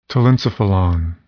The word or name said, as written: telencephalon